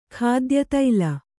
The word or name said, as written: ಖಾದ್ಯತೈಲ